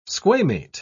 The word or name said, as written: squamate